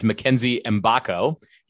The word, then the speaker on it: Mackenzie Mbako: That's Mackenzie Mbako.